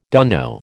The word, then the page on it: dunno: American English